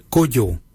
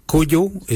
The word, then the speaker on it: Goodjal: Keng is spelled G-E-N-Y. Keng. Number 2, we say Kujo. Kujo for number 2. Kujo is spelled G-O-O-D-J-A-L. Kujo. For 3, we say Marin. Marin is 3. Marin is spelled M-A-R-R-D-I-N-Y.